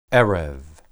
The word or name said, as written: ereb